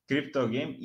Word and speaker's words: criptogame: Criptogame, falei certo? Criptogame.